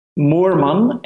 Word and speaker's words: Moerman: It's Bjorn, B-J-O-R-N, Moerman, M-O-E-R-M-A-N.